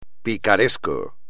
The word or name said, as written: picaresco